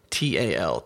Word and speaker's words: TopTal: I mean, I got to admit, if no one has heard of TopTal, and it's spelled TOPTAL, TopTal, like as in top talent,